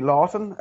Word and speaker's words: Lawton: And my Facebook page is Terry Lawton, LAWTON. It's a bit of a tough one to pronounce when you're Irish. It's an English name. It's always been a nightmare to pronounce.